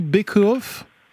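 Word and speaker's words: Baeckeoffe: Est-ce que j'ai bien prononcé bake-off? – Oui, c'est ça, selon le bake-off.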